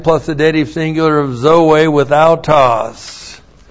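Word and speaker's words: zoe (ζωῇ): Zoe autos. The preposition in, and then zoe, z, long o, long e, n, zoe, life.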